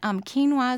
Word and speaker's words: quinoa: Spell quinoa, because I know that's a very strange spelling or strange pronunciation. – It's Q-U-I-N-O-A. – Okay. And that's pronounced quinoa. – Quinoa, yes.